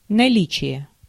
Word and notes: presence: American English; IPA: [ˈpɹɛz.əns]

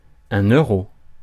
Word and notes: euro: France (Paris); [ø.ʁo]

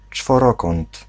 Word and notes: czworokąt: /t͡ʂfɔˈrɔkɔn̪t̪/

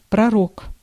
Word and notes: prophet: American English

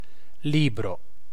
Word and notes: book: /bʊk/

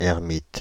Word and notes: ermite: /ɛʁ.mit/

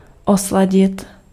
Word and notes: adoucir: France; [a.du.siʁ]

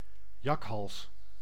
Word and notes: chacal: /ʃa.kal/

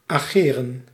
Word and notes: agir: France; /a.ʒiʁ/

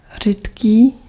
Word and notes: rare: /ˈɹɛə/

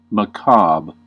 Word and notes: makaber: [maˈkaːbɐ]

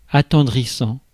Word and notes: attendrissant: France; [a.tɑ̃d.ʁi.sɑ̃]

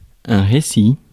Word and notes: récit: [ʁe.si]; France

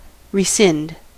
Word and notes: rescind: /ɹɪ.ˈsɪnd/

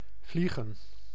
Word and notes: vliegen: /ˈvli.ɣə(n)/

